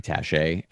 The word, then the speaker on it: Taché: And so for the folks who are listening to this who don't know me, my name is Jeremy Taché, and it's spelled T-A-C-H-E with a little accent mark over the E. And it's super annoying and pretentious, and I wish I didn't have to put it there, but it's necessary so that you pronounce it the right way.